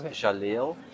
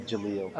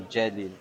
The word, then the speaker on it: Jaleel: It's the English pronunciation. – How do you pronounce it in your language? – I'm born here, so it's Jaleel. – Jaleel. – Okay. Yeah, but it's an Arabic name. It's like one of the 100 names of Allah. So it's the correct pronunciation is still Jaleel. – Okay, cool. – Or Jaleel, but it depends on where you are.